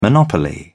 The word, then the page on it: monopoly: British English